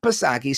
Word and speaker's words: Psaki: I want you to listen to this snippet of stupidity from Jen Psaki, silent P, of course.